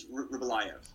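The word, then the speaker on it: Rybolovlev: Is that, am I saying it right? We call him RoboCop because I can't understand his name. But so yeah, I think it's Ryblovlev.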